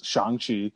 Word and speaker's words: Shang-Chi: The main protagonist clearly is Shang-Chi, who it's Shang-Chi, not Shang-Chi, like we've been pronouncing our entire lives, just because that's how it's read. But when you finally hear it, like, oh yeah, that makes much more sense.